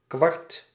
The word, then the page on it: kwart: /kʋɑɾt/; /kʋaɾt/